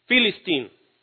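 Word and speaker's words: Philistine: Aparece en la Biblia y convertido al inglés, Se dice con PH, y ustedes saben que la PH en inglés es el sonido de la F. Entonces, Filistín. Y que en castellano se pronuncia como Palestín o Palestina.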